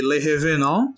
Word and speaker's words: Les Revenants: É, e eu vou deixar uma outra recomendação, que é uma série que é a série Le Revenant, que se escreve Les Revenants,